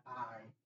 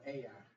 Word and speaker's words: Ai: That's how you pronounce that, Ai, not I, but Ai, which is beside Beth Aven on the east side of Bethel.